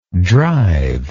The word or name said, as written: drivable